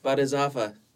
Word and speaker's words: Bodhisattva: Bodhisattva? – Bodhisattva.